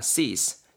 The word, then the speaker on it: cease: cease. cease. 알겠죠? 발음 앞에 좀 구렸습니다. cease. cease. 요게 좀 더 정확한 발음입니다.